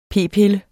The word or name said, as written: p-pille